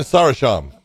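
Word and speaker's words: Gökhan Sarıçam: And you've got against, how do you say his name? Gokhan Sarikham. – Gokhan Sarisham. – Sarisham. I thought it was Sarikham.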